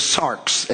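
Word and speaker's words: sarx (σάρξ): The Greek word for flesh, as I pronounce it to you there, is sarx, S-A-R-X.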